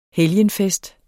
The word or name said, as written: helgenfest